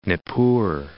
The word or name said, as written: Nippur